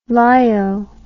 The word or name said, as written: lyo